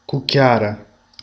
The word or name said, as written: cucchiaio